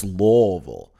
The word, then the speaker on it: Louisville: Because I said Louisville a few times, and someone's like, no, no, no. It's Louisville. And I was like, okay, let's drive over to Missouri after this and go to St. Louis after this.